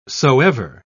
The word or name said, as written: soever